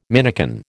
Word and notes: minikin: American English

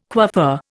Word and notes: coiffeur: Indian

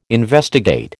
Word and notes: investigate: American English

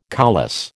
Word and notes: coulisse: American English

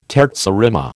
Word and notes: terza rima: American English

